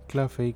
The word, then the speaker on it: Claffey: Claffey, que es un apellido muy difícil de pronunciar. Lo sé, por ende se los voy a deletrear más o menos para que se den una idea. C-L-A-F-F-E-Y.